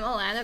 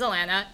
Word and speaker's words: Alanna: Hi, I'm Alanna Vasquez. My name is Alanna. It rhymes with banana. There is no other way to pronounce it. Spelled with one L and two N's.